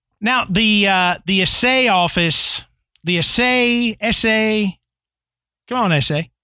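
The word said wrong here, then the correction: assay: Yeah, I think it's a say.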